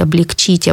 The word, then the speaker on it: облегчить: «Убыстрить», «обострить», «облегчить», «ободрить», «углубить». Вот в этих глаголах всегда будет тоже ударение падать на «ить», кроме единственного глагола «озлобить».